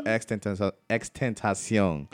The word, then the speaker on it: XXXTentacion: It's actually like he put the X's and basically tentacion, I guess it's Spanish for temptation. – Yeah. – So Xtentacion.